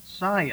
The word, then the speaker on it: Sion: This land extended from Aroer on the rim of the Arnon Gorge to Mount Sion, I think is the way that's ordinarily pronounced,